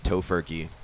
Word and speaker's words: Tofurkey: They eat tofurkey. – Tofurkey? – It's tofu turkey. – How do you say that? Tofurkey. – Exactly the way you just did.